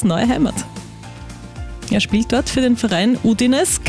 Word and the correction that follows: Udinese: Calcio, oder habe ich das richtig ausgesprochen? – Udinese. – Udinese Calcio, gut, er ist jetzt dort offiziell präsentiert worden.